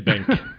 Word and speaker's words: BenQ: BenQ? How do you pronounce the monitor brand? BENQ. – I think. – Anybody know? – I say Benq.